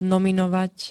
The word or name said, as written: nominovať